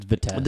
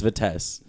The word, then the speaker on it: Vitesse: Vadiz does all right with their Chelsea team too. – Yeah. It's God, your pronunciation on shit is so whack. – Vitesse. – It's Vitesse. Yeah.